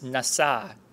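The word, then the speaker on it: nasa (נשא): And the word is nasa. You could spell it N-A-S-A, nasa, to lift up.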